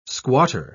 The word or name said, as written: squatter